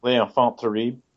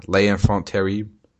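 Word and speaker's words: Les Enfants Terribles: And it ends with I can't ever pronounce it, the L'Enfant Terrible. – L'Enfant Terrible. – Yeah, L'Enfant Terrible.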